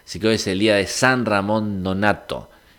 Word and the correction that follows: Nonato: Nonato, lo digo bien.